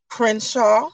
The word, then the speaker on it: Crenshaw: It's Erica, ERICA, Crenshaw, CRENSHAW.